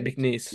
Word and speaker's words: McNeese: McNeese.